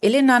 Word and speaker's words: Helena: Also, mein Name ist Helena auf Hanos Böckel. Im Griechischen spricht man das Haar nicht, deswegen ähm, nenne ich mich selber und alle anderen ähm, können mich auch Elena nennen.